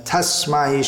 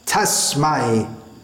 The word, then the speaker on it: tasmai: Commonly, this will be pronounced Om Jnana Timirandhasya, but that sir should be pronounced Tasmai, not Tasmai. That sir should not be softened to a z sound.